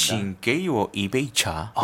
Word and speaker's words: 请给我一杯茶: 징게이워 이베이차.